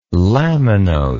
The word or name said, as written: laminose